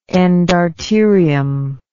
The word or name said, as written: endarterial